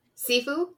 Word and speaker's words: Sifu: So, uh, Sifu, am I saying that correctly? – Yeah.